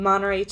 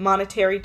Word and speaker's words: Monterey: It's called Monterey chicken. People would pronounce it monetary chicken.